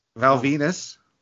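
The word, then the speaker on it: Val Venis: Val Venus. – No, he's a batshit crazy guy now. – Rhymes with penis.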